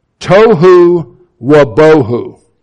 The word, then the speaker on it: tohu wabohu: Tohu wabohu. Okay? That's how you say it in Hebrew.